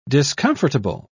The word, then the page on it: discomfortable: British English